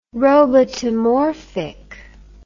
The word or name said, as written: robotomorphic